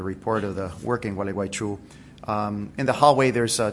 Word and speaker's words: Gualeguaychú: but also Gualeguaychú side there in Argentina. The city is, it's good for wintertime illnesses. If you sneeze or have allergies, you can pronounce it well. It's Gualeguaychú.